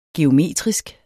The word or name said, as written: geometrisk